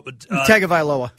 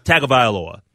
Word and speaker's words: Tagovailoa: Tua Tagovailoa. – Tagovailoa, the starting quarterback for the Miami Dolphins.